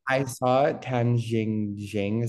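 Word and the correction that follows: Tang Xijing: Is that how you pronounce her name? – Tang Shi Jing.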